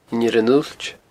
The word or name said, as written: Inbhir an Uillt